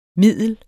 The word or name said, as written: middel